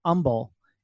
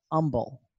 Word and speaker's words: Humble: Like, right outside of Houston, there's a town called Humble. It's spelled with an H. It's spelled with an H, but we all call it Humble. Instead of Humble, we call it Humble.